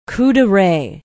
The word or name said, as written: Couderay